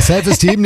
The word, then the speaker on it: Self Esteem: The Offspring, Self Eastern. – Ja, die Aussprache ist ein bisschen. – Steam, he's Steam. – Ja. Ja, ja, ist richtig. – Ja, Self Esteem nehmen wir auch.